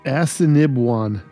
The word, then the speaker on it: Assiniboine: It's pronounced Assiniboine. Yes. – So it's not like Assiniboine. It's not Assiniboine. – No, it's closer to a Cinnabon.